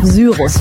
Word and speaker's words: Syrus: So, da können wir uns jetzt drüber streiten, ob das die englische oder schriftgesprochene Aussprache ist. Ich habe mich jetzt einfach mal auf Syrus festgelegt. Schreibt sich S-Y-R-U-S.